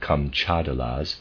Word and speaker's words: Kamchadales: Certain uncivilized people belonging to the Kamchadalas, I'm going to pronounce it Kamchadalas, it's spelled, reader aside, it's spelled K-A-M-C-H-A-D-A-L-E-S, Kamchadalas, end of readers aside, believe that the water wagtails, or similar birds, bring on springtime, because spring follows their arrival.